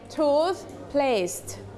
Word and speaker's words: toothpaste: มีตัว S ด้วยนะคะมีตัว T ด้วยนะคะ We can pronounce toothpaste.